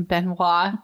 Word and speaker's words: Benoit: If you look, their last name is spelled B-N-O-I-T, and I kept pronouncing it wrong. And Mike is like, if you fucking say anything other than Benoit, like, you are going to cause a problem.